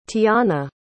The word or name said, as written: tiana